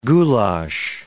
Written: goulash

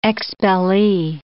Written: expellee

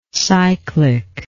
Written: cyclic

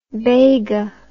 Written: baga

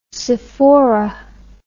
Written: sophora

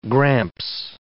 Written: gramps

